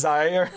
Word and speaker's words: Zaire: Zaire. – This. Zaire.